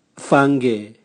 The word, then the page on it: vange: Raga